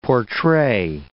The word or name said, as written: portray